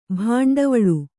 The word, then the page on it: ಭಾಂಡವಳು: bhāṇḍavaḷu